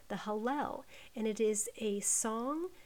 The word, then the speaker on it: Hallel: Hallel sounds like hallelujah because it's spelled H-A-L-L-E-L.